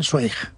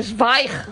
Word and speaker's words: Zweig: Tú sabes que yo toda mi vida he estado diciendo que yo leía Stephen Zweig. – Ah, claro. Es no. Zweig. – Zweig.